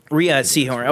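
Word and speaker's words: Rhea Seehorn: But Bob Odenkirk in this, specifically Bob Odenkirk and, I always forget how it's pronounced, Ray Sehorn, Kim Wexler. Rhea Sehorn.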